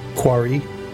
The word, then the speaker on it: Quarriskt: Quarry. – Yeah? – I mean, that's probably... – Is silence? – Yeah, everything past the S-K-T is silence.